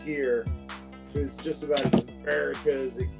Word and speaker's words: Merrikah: This one's, that is America. – It says America. And it's an Instagram comic. I think it's, if I'm not mistaken, America_comic. – Okay. – And it's spelled MERRIKAH.